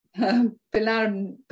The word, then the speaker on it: Pylarinou: And how do you pronounce that? – Pilarinu.